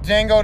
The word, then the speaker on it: Django: The Django, the D is silent.